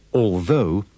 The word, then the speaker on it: although: Pay attention to the pronunciation of the words although and though. The O-U-G-H at the end is pronounced with the sound of the O in go.